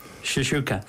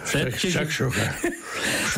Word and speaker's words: Shakshuka: Shishuka. – Shakshuka.